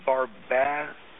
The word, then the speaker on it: Barbadensis: What you really want to get is you want to get what's called aloe vera barbadosis. I'll try to spell it for you because I didn't pronounce it right. It's Barbadensis. Barbadensis Miller.